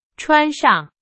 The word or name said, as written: 穿上